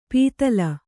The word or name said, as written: ಪಿತ್ತಲ